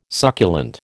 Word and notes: succulent: American English

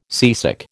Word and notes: seasick: American English

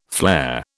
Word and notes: flare: British English

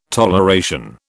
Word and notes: toleration: British English

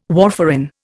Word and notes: warfarin: Indian

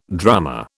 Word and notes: drummer: British English

